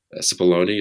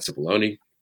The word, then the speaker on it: Cipollone: Cipollone, is that, I think that's how he pronounces his name, Pat Cipollone.